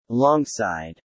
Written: alongside

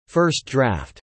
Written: first draft

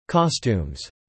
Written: costumes

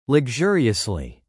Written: luxuriously

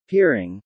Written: peering

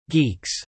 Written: geeks

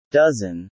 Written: dozen

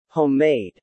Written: homemade

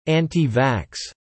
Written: AntiVax

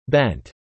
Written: bent

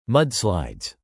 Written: mudslides

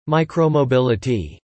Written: Micromobility

